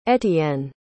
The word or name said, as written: etienne